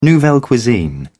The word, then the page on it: nouvelle cuisine: British English